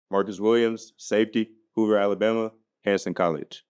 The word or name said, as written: Marcus Williams